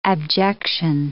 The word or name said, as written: abjection